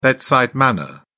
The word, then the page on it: bedside manner: British English